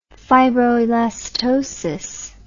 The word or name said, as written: fibroelastosis